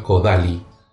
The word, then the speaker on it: Kodály: Se escribe, si lo leemos en español, Kodály, pero se pronuncia Kodály,